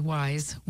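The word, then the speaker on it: Weis: And it was kudos to Weiss's Market in mm-hmm. Clark Summit. It was a gluten-free cake. – Yep. – I'm sorry, Wise, you're right. That's the way they pronounce it.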